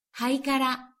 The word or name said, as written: haikara